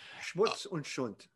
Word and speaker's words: Schmutz und Sünd: He's fighting against the pornography, Schmutz und Sünd. I don't think I said it right. Thank you. – Schmutz und Sünd.